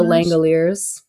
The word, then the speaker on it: Langoliers: The Longiners? – The Langoliers.